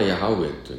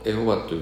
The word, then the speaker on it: ヤハウェ: ですから、まあ、正式な発音はヤハウェというんですけども、まあ、でも、まあ、あのエホバというふうに翻訳したからって別にもある、うん、まあ、まあいいんじゃないかと思うんですね。うん、で、まあ、イザヤというね、この預言者、神の名前を持っているわけですから、まあ、大預言者。